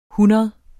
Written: hundrede

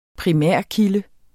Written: primærkilde

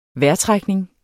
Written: vejrtrækning

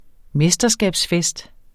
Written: mesterskabsfest

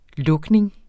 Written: lukning

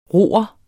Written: roer

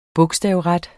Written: bogstavret

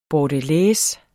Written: bordelaise

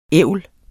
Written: ævl

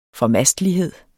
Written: formastelighed

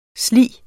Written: slig

